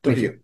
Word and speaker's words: Trujillo: Played by Raul Max Trujillo. Am I pronouncing that correctly? – Yeah, Trujillo.